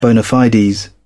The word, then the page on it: bona fides: British English